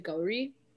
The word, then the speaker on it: Gauree: I think part of it is also, like, I can't really insist on a correct pronunciation because people from different parts of India say my name differently. – Mm-hmm. – Like, so I'm, like, South Indian, and my family says it Gauree.